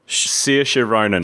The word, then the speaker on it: Saoirse Ronan: So uh, so say her name again, so I don't say it wrong. Saoirse Ronan.